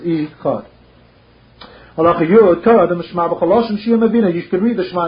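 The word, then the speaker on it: echad (אחד): Also, the Rambam said you have to pronounce the ches carefully, so it should sound like echad and not ichad.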